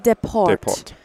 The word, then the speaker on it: DesPortes: DesPortes. Is that how you say it in French? – DesPortes.